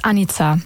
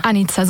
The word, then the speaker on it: Anitza: Ganz perfekt ist Anitza. – Anitza. – Anitza, so wie Annika, aber mit Z ausgesprochen.